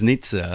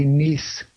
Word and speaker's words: Nice: You see, because when I read it, I read it as Nizza. I pronounce it as a European. – It would be Nice in France and the rest of Europe, as far as I know. Nice is the way to pronounce it.